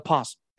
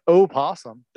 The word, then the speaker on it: opossum: It's an opossum. That's what it actually is. – The O is not so silent. – No, it's not. No, if you say so possum, you just spell it with a P.